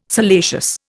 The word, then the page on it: salacious: Indian